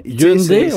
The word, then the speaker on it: Hyundai: Est-ce qu'on t'a dit, en terminant, comment bien prononcer le mot Hyundai? – Parce que c'est pas tout le monde qui l'a, là. – Ouais, des fois, on entend Hyundai, Hyundai. – Hyundai? – Ouais, Hyundai, oui.